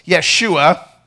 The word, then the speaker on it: Yeshua: Jesus is not really the way it was pronounced in the original, right? That wasn't really the way it was said. It was Yeshua, and we would more correctly pronounce his name today as Joshua, okay? So it's actually the same as Joshua, yet somehow we turned it into Jesus.